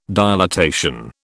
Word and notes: dilatation: British English

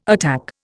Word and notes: attack: Indian

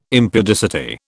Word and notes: impudicity: British English

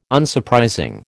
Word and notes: Unsurprising: American English

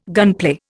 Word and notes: gunplay: Indian

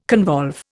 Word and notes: convolve: Indian

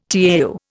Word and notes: tau: Indian

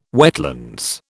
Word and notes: wetlands: British English

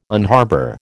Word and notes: unharbour: American English

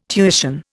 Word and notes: tuition: Indian

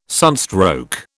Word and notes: sunstroke: British English

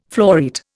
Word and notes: floreat: Indian

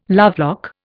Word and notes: lovelock: Indian

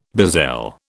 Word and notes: bezel: British English

